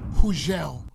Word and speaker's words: Hugel: Hugel. – Yes. – And the G is silent.